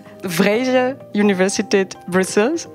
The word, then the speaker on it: Vrije Universiteit Brussel: Nice to have you with me today for this episode at VUB, the Vrije Universiteit Brussels.